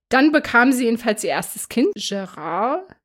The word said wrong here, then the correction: Jared: Nee, ich muss ja Englisch aussprechen. Jared, sorry.